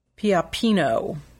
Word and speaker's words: Pioppino: Piopino, that's spelled P-I-O-P-P-I-N-O.